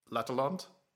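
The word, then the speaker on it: L'Atalante: Number 3, L'Atelante. Did I say it right? – Yeah.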